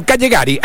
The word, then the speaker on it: Callegari: Es Stefano Callegari, el zaguero central, se escribe con ella, pero se... Bueno, Callegari, hablemoslo así.